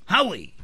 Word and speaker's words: Huawei: Highway, que tiene la mejor cámara, güey, de, los celulares. ¿Cómo se dice? Huawei. – Huawei. Nosotros lo conocemos como Highway, güey, ya.